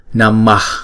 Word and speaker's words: Namah: Ví dụ, chữ Namah chúng ta sẽ đọc là hai nhịp, bởi vì hai chữ A đều là một nhịp.